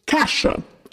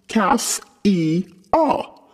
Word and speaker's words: Cassia: When I watched the interview, I saw that you pronounced Cassia's name Kasha. Please do not get offended, but frankly, I was shocked and a bit upset. I always thought her name was pronounced Cass-E-A, like Cass-E with an A at the end.